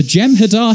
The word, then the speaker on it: Jem'Hadar: And we're going to begin with, I've just got to get this pronunciation right, the Jem'Hadar HR. – All right, good job, you got it.